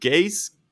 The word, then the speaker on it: Ghais: Also, ich habe gehört noch von, ähm, äh, ich weiß, wie der ausgesprochen wird. Gays, Guevara. Gays ja, ey, du lachst, ne? Aber ohne Scheiß. Da wird halt geschrieben G-H-A-I-S. – G-H-A-I-S. Wie willst du das denn sonst aussprechen? – G ja, nee, wie lustig.